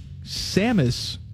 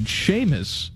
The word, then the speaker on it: Samus: My tool of the week is Bone for calling Samus from Metroid Seamus.